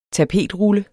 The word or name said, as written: tapetrulle